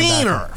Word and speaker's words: Fleener: I always say Freeney. – Yeah, you get the line. – Fleener. Fleener. – Yeah. – Rhymes with Wiener.